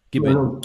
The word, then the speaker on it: Gießen: Gießen, is that correct? Have I pronounced that correctly? – Gießen.